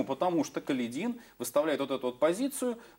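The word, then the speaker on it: Каледин: А донской атаман Калидин, правильно, кстати, это вот ударение, Калидин, донской атаман Калидин считает, что нельзя давать им официального прикрытия.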